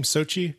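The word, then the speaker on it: Xochitl: The actress, I think they pronounce her name Sochi, even though it's not spelled that way.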